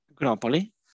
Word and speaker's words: Agropoli: sort of south of the city of Agripoli. How do you say it? Agripoli? – Agropoli.